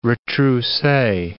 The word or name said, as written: retrousse